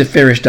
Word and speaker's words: Feres: So the first of four truths is the Farish Doctrine. That's one that you have to get in your brain. It's spelled F-E-R-E-S, Farish Doctrine, D-O-C-T-R-I-N-E.